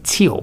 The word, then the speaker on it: Thiel: Yes, in fact, kicking it off, they've got Peter, is it Thiel or Thiel? – I pronounce it Thiel, but I don't.